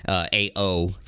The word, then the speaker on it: Ao: The only one who does is the main character named Ao, A-O for its spelling, but it's pronounced Ao.